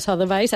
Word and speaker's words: Sotheby's: Com se pronuncia? Perquè no ho han sabut mai. – Nosaltres, a París, sempre, sí, sempre ho hem pronunciat Sotheby's. – Sotheby's.